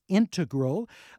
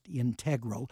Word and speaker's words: integral: It is integral, just like our listener said, not integral.